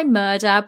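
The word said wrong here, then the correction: Murdaugh: but apparently it's pronounced Murdoch.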